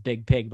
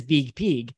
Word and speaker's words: Biig Piig: If you remember Big Pig, and the reason I say it like that is B-I-I-G-P-I-I-G. Now, I'm sure it's pronounced Big Pig, but I like it as Big Pig.